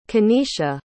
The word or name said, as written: keneisha